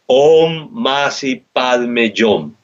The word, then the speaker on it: Om Mani Padme Hum: Om Masi Padme Yom. Así lo pronunció el maestro. Así me enseñó a pronunciarlo. Y se escribe Om Mani Padme Hum. Y decimos, dirigiéndonos a Michael, Om Masi Padme Yom, viendo el sol y viendo un ángel de oro en el sol, a Michael interior.